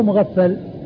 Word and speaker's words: مغفل: شخص واحد هو مغفل وهو والد عبد الله الصحابي الصحابي عبد الله بن مغفل والده مغفل بالغين والفاء المشددة. من سواه في صحيح البخاري بهذه الصورة فهو معقل معقل بالعين والقاف بالعين الساكنة والقاف المكسورة.